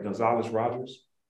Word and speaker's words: Gonzales-Rogers: So I'm going to say angel leader, but Dr. Patrick, and if I'm saying it right, Gonzales-Rogers? – Yeah.